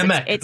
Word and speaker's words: Kimmick: Kimmick, right? Am I pronouncing it right? – Because Kimmick, that's correct. – Kimmick, which is comic backwards.